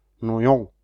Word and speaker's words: Noyon: Wir haben Noyon geräumt.